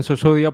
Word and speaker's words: Sussudio: Adrián Susudio, pues me suelen decir, si quieres pronunciarlo en inglés, como Phil Collins lo pronuncia, él lo decía Susurio, pero con Susudio mismo ya está bien.